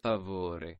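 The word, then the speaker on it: per favore: TBM, one more left turn, per favor. – Okay, well, notwithstanding your dangerous and frightening left turns there, Joe, my friend says the correct pronunciation is... – favore.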